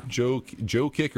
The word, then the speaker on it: Jokic: Yeah, Joker. – Jokic or Jokic. I keep getting him mixed up. – It's Jokic, but the way it's spelled, it's Joker.